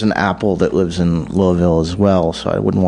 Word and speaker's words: Louisville: Chad lives in Louisville, or Louisville, I'm supposed to say. Damn. – They want less syllables on that. – Louisville.